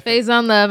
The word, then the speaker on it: Faizon Love: And then, shout out Fat Faye, how you say it? – Faze on Love, I'm not going to repeat it.